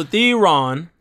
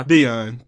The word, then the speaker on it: Theon: So, Theron. – Theron. – How do you say his actual name now? – Theon.